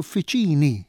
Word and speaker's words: uffiċini: Issa, meta aħna mbagħad niġu għal kliem liħol li semmejt uffiċjali, pereżempju, jew uffiċini, l-aċċent m'għadux fuq iċċ. Imma fil-każ ta' uffiċjali, l-aċċent sar fuq ja, fuq is-sillaba ta' qabel tal-aħħar. U l-istess uffiċini fuq ċi.